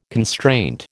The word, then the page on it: constraint: American English